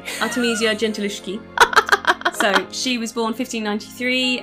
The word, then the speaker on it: Artemisia Gentileschi: So, I'm going to be talking about Artemisia Gentileschi.